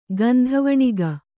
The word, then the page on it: ಗಂಧವಣಿಗ: kannada